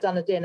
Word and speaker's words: Dunedin: um is that the same for you in dunedin tell us a little bit about dunedin and the city of literature so